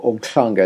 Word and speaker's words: Umhlanga: I have memories of my holidays in in South Africa, and particularly one in Umhlanga, which, or Umhlanga, as you probably pronounce it, which is near Durban.